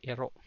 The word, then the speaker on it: iro: What is an iro? So iro. How do you spell that? Iro. Iro with a glottal catch at the end means dog.